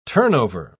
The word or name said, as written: turnover